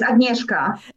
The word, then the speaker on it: Agnieszka: Yes, Agnieszka.